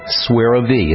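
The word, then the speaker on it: Suerovie: S is in Sam, U-E-R-O-V-I-E, Swerve V.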